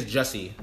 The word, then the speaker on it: Jussie: You said Jussie? – It's Jussie.